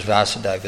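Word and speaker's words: Vāsudeva: Well, Krishna is known as Vasudeva with a long A, isn't it? Yeah, because he's the son of Vasudeva with a short A.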